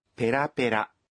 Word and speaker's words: ペラペラ: 对，表示这种含义的时候，轻重音有所变化，要读作ペラペラ。